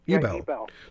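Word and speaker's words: Ebell: So, how do you pronounce the name of is it Ebel or Ebel? – Ebel.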